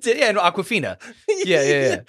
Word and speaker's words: Awkwafina: Awkwafina is how you pronounce it.